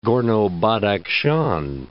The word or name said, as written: Gorno-Badakhshan